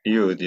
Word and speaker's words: Yud: Because I would say why, but in the Hebrew, it's how do you say it? It's Yud? Is that what you say? – Yud, yeah. But you can call him Y.